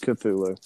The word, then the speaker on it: Cthulhu: And then Cthulhu shows up, or Califlu, whatever. Apparently, we've been saying it wrong this whole time. – No, it's Cthulhu.